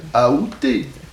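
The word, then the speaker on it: aoûté: La prononciation qui est considérée comme correcte, c'est la prononciation avec A. Aouté.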